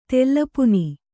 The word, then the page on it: ತುಲಾಪಾನ: Kannada